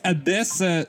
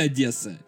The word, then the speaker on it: Одесса: Просто любят говорить Одесса с «э», это неправильно. Говорите Одесса, это правильное произношение.